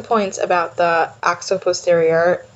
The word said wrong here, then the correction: occiput posterior: Is that how you say it? – Occiput posterior, or we just call it OP.